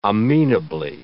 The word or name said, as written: amenably